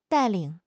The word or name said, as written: 带领